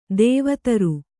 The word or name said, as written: ದೇವದಾರು